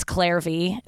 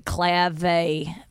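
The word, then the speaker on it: Clare V.: But yeah, and I just, Kimmel gave me a purse for guest hosting, really nice purse from Clave, which is, it's Claire V. It's like a nice like leather company, but in purses and stuff. But it's Australian, so I always pronounce it Clave because that's how they talk.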